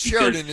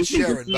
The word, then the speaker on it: Sheeran: It's not Sheridan, it's Ed Sheeran, by the way.